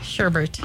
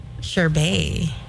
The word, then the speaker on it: sherbet: Sherbert. Got it. – Got it. All right. – Sherbet. I'm going to make everybody start saying it that way. – Oh, you know, it's actually pronounced sherbet.